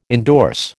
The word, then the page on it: indorse: American English